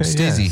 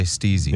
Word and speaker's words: Stizzy: Am I saying that correctly? – Okay, Stizzy. – Yeah. Stizzy. – I think they want us to say it Stizzy. – I think it's Stizzy.